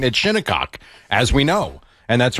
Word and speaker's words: Shinnecock: Shiny Cock? – Well, I mean, we call it Shinnecock here, so, I mean, different pronunciations for different people.